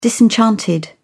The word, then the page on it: disenchanted: British English